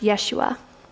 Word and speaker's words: Yeshua: Jesus is a Greek translation of the name Joshua, and in fact, Jesus' name would have been pronounced Yeshua.